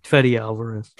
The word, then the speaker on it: Fede Alvarez: Fede Alvarez.